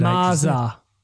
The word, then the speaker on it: MZHZ: It's called MZHZ. – MZHZ. – Maza is how they pronounce it.